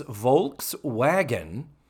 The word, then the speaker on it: Volkswagen: It is Volkswagen. And, and in German, I am told that the V is pronounced more like an F.